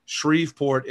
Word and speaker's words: Shreveport: Shreveport is how it's pronounced.